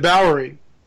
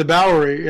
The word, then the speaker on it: Bowery: And what was interesting is, could it have been that what we saw in the Bowery, I finally said it right, in the Bowery in on Skid Row, was some sort of a fallen angel masquerading as a human.